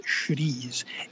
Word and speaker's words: chouriço: If you prefer spicy, and maybe after you try the linguiça, you want to get a little adventurous, try chouriço, which is spelled chorico, but it's pronounced chouriço.